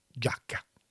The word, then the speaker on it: giacca: Pour prononcer giacca, Je l'ai sous les yeux, moi. – G-I-A pour faire gia. – Ouais. – G-I-A. Le I, on ne le prononce pas, mais il a pour faire je.